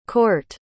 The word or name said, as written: kort